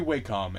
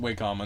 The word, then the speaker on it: Wacom: It should be Wacom, and everyone's pronouncing Wacom until they talk to someone from the company and they say Wacom, and they're like, "No, it's not Wacom." And they're like, "We work for it. We know."